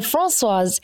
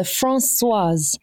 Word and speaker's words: Françoise: And so Françoise is almost in two syllables. You've got France, like the country France, and then Swaz, that is the second syllable. So Françoise.